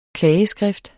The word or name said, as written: klageskrift